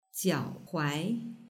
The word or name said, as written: jiaohuai